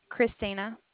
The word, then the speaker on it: Christina: Christina. – Christina. H-R-I-S-T-I-N-A.